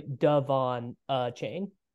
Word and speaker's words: Devon Achane: Devin Ashane pronunciation. It's Devin Devon. It's actually Devon Chain. – Okay, I'm out. So Charbonnet in his own tier. Tier four, everyone but Devon Achane.